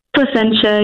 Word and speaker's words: Placentia: Is it Placentia? – Placentia, yeah.